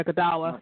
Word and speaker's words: Godawa: And I'm not sure how you say his name, but Brian Godawa. – Yeah, Godawa.